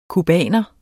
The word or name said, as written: cubaner